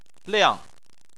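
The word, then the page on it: liang: tone 4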